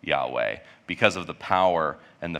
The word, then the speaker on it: Yahweh: And so the one that we're probably the most familiar with is Yahweh, which is often spelled without an A and an E. So it's Y-A-H-W-E-H.